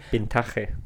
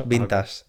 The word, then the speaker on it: vintage: Vintaje. no, lo pronunciamos vintage.